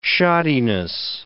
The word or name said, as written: shoddiness